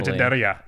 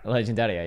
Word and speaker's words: Legendaria: Legendaria. Legendaria. Yeah, that's exactly how you say it in Italian.